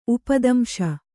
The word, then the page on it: ಉಪದಂಶ: upadamśa